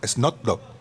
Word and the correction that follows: Snoop Dogg: Snoop, Dogg.